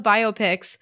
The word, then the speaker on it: biopic: And I was like, or wait, Okay. They said it correctly. Now, okay. See, I'm overthinking it. Now I'm nervous. I'm sweating. Biopic. Oh, gosh. – That's the right way to say it.